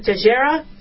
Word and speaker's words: Teixeira: But Jack Teixeira, I don't know if it's not Teixeira, it's Teixeira, I want to make sure I'm pronouncing it right,